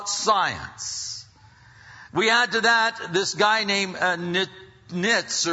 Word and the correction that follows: Nietzsche: It's N-I-E-T-Z-S-C-T-H-E. How do you pronounce that? Nietzsche, then. Great.